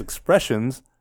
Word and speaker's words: Expressenz: So their name, the way they say it is Expressions. But it's spelled EXPRESSENZ.